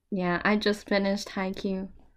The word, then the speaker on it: Haikyuu: Haikyuu? Is that how you pronounce it? – Haikyuu, yeah.